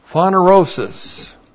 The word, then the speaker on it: phanerosis: And then we have phanerosis. Looks like this in the Greek. P-H-A-N-E-R, long O-S-I-S.